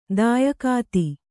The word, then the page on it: ತ್ಯಕ್ತ: tyakta